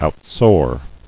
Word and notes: Outsoar: out·soar

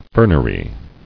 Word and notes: Fernery: fern·er·y

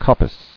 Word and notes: Coppice: cop·pice

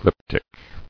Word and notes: Glyptic: glyp·tic

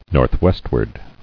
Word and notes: Northwestward: north·west·ward

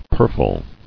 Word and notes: Purfled: pur·fle

